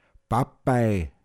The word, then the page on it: as Bett balantan: Pinzgauer Mundart